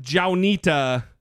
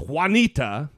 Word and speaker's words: Juanita: It's not Jaunita Broaddrick, it's Juanita. It's spelled like Juanita.